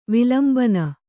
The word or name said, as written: ವಿಲಂಬನ